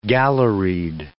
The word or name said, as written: galleried